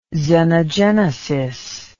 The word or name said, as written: xenogenesis